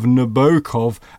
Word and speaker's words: Nabokov: My New England ear is not offended by the long, elegant middle O of Nabokov, as delivered in American academies.